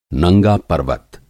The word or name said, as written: नंगा-पर्वत